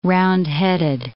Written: roundheaded

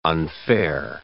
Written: unfair

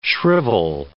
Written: shrivel

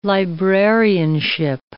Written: librarianship

